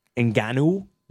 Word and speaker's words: Ngannou: But the big thing that came out of last night overall is Francis Ngannou is the way you pronounce his name.